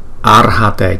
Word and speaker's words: Arhate: Chữ R này chúng ta đọc là R. Arhate chứ không phải là Ara, nhưng mà Arhate, Arhate, Arhate.